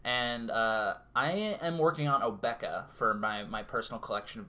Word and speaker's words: Obeka: But I prefer to pronounce it Obeka specifically because it rhymes with Topeka.